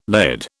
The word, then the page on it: LED: British English